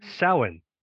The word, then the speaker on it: Samhain: It is pronounced Samhain.